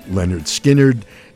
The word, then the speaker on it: Lynyrd Skynyrd: Lynyrd Skynyrd from their debut record, pronounced Lynyrd Skynyrd, and Tuesday's Gone.